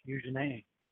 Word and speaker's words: Eugenie: I just know how to say her name because it's French, Eugenie.